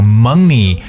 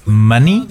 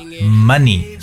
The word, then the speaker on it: money: 其实说到这个单词呢，很多人首先要把这个词儿要读对，因为我发现很多同学对 money 这个词的读音呢会把它读错，要么读成 money 还是什么的，but it's money。 OK，这个短音啊 money，所以说喜欢金钱呢，要把金钱这个词要说正确。